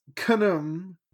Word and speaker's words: Khnum: And Carter's like, well, so it's pronounced Khnum.